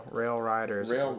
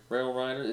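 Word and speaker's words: RailRiders: Is that a Rail Raiders? – Rail Riders. – Rail Riders.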